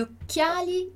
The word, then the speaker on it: occhiali: E o CH, que a gente já sabe que é o nosso som de QU ou de K. Então, CH aqui tem dois Cs, né? CCH. A gente alonga a pronúncia, demora um pouquinho mais ali. Gli occhiali, occhiali.